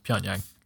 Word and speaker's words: Pyongyang: Shit, I totally mispronounced the capital city. Pyongyang.